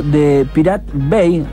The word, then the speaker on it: The Pirate Bay: Habló de esta decisión de la Comisión Nacional de Comunicaciones, que decidió bloquear un sitio llamado The Pirate Bay.